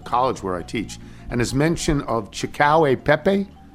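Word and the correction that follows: cacio e pepe: is that how it's pronounced? – Cacio Pepe. – Cacio Pepe, okay.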